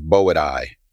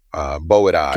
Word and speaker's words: Boidae: Boyds is, Boyds describes, so in the phylogenetic tree, the family name of constrictor snake is Boidae. Boidae. – Got it. – So it's spelled like Boyds, but with an A-E at the end.